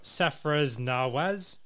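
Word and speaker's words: Sarfraz Nawaz: All right, Safraz Nawaz and Abdul Qadir. – I think you said those right. I'm pretty sure.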